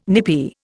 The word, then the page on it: nippy: Indian